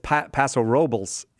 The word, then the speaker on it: Paso Robles: Yeah, it's Paso Robles if you're from the north. Yes, it is. Yes, north of where you're from. But you pronounce it with the Spanish pronunciation if you come from Southern California.